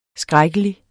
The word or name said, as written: skrækkelig